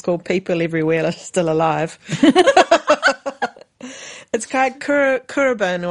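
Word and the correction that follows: Khruangbin: I need to re-pronounce that. So it's Krungbin.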